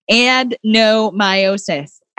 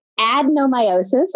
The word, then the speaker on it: adenomyosis: Adenomyosis. See? – There you go. Yes, adenomyosis.